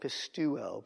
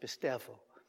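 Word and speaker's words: pistevo (πιστεύω): It looks like pistuo, but it's pronounced pistevo.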